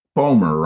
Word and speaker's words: Spomer: Spomer rhymes with Homer and Gomer.